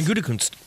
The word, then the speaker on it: Gutekunst: Brian Gutekunst. – Yeah. – But there is an N in there, right? – Yeah, there is. – It's Gutekunst. – Correct. – Gutekunst.